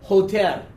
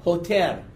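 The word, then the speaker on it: Hoter (חוטר): The rod there, the rod is called a koder. I pronounced that wrong. Try it again. Hoter. Hoter. C-H-O-T-E-R. C-H-O-T-E-R. The pronunciation is Hoter. Okay? And the branch is Netzer. Netzer. N-E-T-Z-E-R, N-E-T-Z-E-R, Neser.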